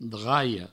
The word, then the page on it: draia: draio; Provençal